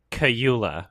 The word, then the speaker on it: Kaluuya: And what I really love about the acting in this movie is like Daniel Kaluuya and Lakeith Stanfield.